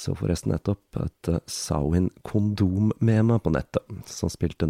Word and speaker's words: Samhain: Sawin, som eh, skrives Samhain, men som jeg forsøker å uttale på gallisk som best jeg kan,